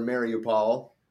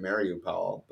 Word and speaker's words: Mariupol: That being said, Mariupol, or Mariupol, the Americans seem to pronounce it Mariupol, but everybody else says Mariupol.